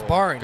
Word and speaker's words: Borange: And that boundary was sponsored by Borange. You need something that rhymes with orange. You better believe it's Borange.